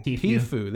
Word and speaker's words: Tfue: Tfue, There you go.